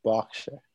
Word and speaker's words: Berkshire: So if you've ever been to, now this is spelled Berkshire, but pronounced Berkshire.